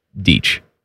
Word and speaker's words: Deitch: He's Swiss. No, he's Deitch. – He's Deitch. – Yeah. And I say it like Spice.